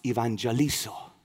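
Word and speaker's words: evangelizo (εὐαγγελίζω): As a matter of fact, that word good news in Greek is pronounced evangelizo.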